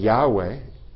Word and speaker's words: Yahweh: Jehová realmente no era el nombre de Dios, es nada más la mezcla de Yahweh, el símbolo que se pronuncia Yahweh, y Adonai.